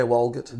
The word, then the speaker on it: Walgett: I'm pronouncing that right, aren't I? – Yeah, Walgett.